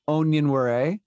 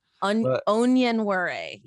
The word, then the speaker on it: Onyenwere: But Michaela Onyenwere. – onion worry. Yeah.